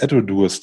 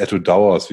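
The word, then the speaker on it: Edradour: Äh, da gibt es nämlich immer auch diese Etodurs drin oder Etodowers, wie man es auch aussprechen möchte.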